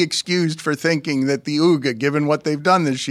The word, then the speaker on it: Wooga: You're the first person I've heard pronounce Wooga Poplar's name that way. Just Uga.